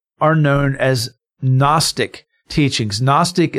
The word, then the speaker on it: Gnostic: Okay, so Gnostic, other than telling us it has that lovely silent G, what is Gnostic teaching?